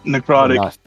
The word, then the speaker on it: Necrotic: It's uh, narcotic in a way. Narcotic. Ner- Did I say that right? – Necrotic.